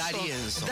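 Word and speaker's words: D'Arienzo: ¿Está bien pronunciado Arienzo? – Darienzo. – Juan de Arienzo. Sabía que lo estaba pronunciando mal.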